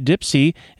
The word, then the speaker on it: DIYpsi: But meanwhile, the DIY Ipsy Festival, actually correctly pronounced Dipsy, is very close to Depot Town.